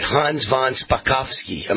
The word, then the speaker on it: Hans von Spakovsky: Let me make sure I'm saying this right. Hans von Spakovsky. Am I saying that right? – You got it, yeah.